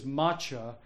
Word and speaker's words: matcha: So the tea is matcha, as it is pronounced in Japanese, a green tea, a powdered tea that you'll witness me prepare for you.